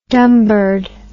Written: dunbird

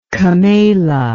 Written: kamala

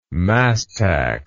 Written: mastax

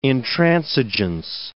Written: intransigence

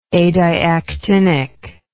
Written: adiactinic

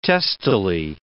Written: testily

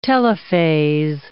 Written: telophase

